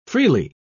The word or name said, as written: freely